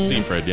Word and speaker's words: Seyfried: You know, Amanda Seyfried or however Seyfried. You say her name. – Seyfried, yeah.